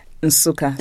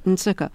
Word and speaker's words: Nsukka: You were born in a town called Nsukka, am I saying that right? – Nsukka. So the N is hummed. – Yes, Nsukka.